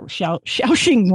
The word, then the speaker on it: Shaoxing: Xingshao wine, pardon me, I pronounced that, Shaoxing wine. Pardon me, to everybody who speaks Chinese and is laughing at me now, wine, sugar, and spices like star anise and Sichuan peppercorns.